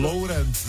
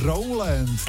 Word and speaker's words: Rowland: Lowrand. – Kerry Lowrand. – Rowland.